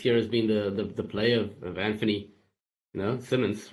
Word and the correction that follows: Simons: right? Simons, how do I say it? – Simons, I think, yeah.